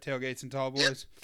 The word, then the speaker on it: TailgateNTallboys: Tailgates and Tall Boys.